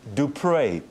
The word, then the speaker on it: du Preez: If you realize it's a French name, it's as in Dupré.